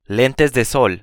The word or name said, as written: lentes de sol